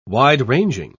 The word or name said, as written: wide-ranging